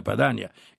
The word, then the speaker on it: Padania: Quindi anche Padania, nella nostra pronuncia, si pronuncia correttamente Padania e non Padania,